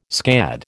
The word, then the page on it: scad: American English